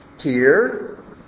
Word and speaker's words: tear: T-E-A-R. How do you pronounce that? Oh. Tear, like a teardrop, or tear, like tearing a piece of paper.